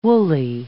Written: woolly

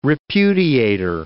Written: repudiator

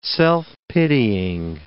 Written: self-pitying

